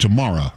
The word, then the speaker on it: Tamara: It's like tomorrow, except Tamara, right? – You got it.